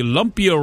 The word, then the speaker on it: Lumpia: Lumpia? L-U-M-P-I-A. Am I saying that correctly? – Lumpia? – Lumpia City. Sure.